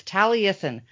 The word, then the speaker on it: Taliesin: It's Taliesin.